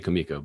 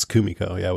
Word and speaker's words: Kumiko: Mine is they say Kumiko, right? Or Kimiko? I want to say Kimiko, but it's Kumiko, huh? – I think it's Kumiko, with the emphasis on the first syllable.